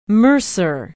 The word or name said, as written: mercer